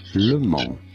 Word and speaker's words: Le Mans: In France, it is said as Le Mans. Yes, okay. The S is silent here. Le Mans. – Lemon, dude.